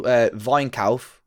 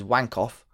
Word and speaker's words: Weinkauf: Guy called, it's called Weinkauf, or if you're English, you'd pronounce it as Wankoff.